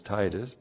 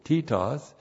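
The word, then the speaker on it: titos (Τίτος): You know him as Titus, but in the Greek you pronounce it titos.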